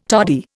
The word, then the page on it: toddy: Indian